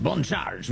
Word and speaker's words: Bon Charge: Bon Charge, we say with a slight French accent so I can pronounce that B-O-N perfectly.